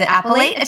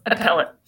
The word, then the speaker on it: appellate: She is the mother of three children and is a practicing, is an appellate attorney? – Close. Appellate.